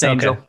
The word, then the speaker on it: Angell: So, is your last name pronounced Angel? Is that right? Yep, you nailed it. It's Angel. Okay. – okay. We'll make sure it wasn't like angle or, you know, some unusual pronunciation or something.